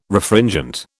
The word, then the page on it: Refringent: British English